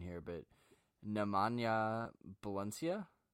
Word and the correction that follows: Bjelica: Nemanja Bjelica. Bjelica.